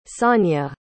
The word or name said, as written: sanya